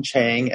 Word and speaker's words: Chiang: You can find me at Austin Chang, MD. My last name is spelled C-H-I-A-N-G.